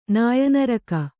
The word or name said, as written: ನಾಯನರಕ